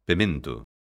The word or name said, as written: pemento